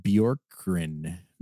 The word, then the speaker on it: Bjorkgren: Their coach, Nate Yorkin, I don't know how to say his name. – The Pacers coach? – Yeah. – Good question. – He's a first-year coach. Nate Bjorkgren.